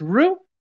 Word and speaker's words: Roux: This is where they met the Princess Roux. R-O-U-X. I know it's spelled weirdly, I didn't know on purpose. – Like the sauce.